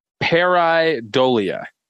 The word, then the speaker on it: pareidolia: The word for this week is pareidolia.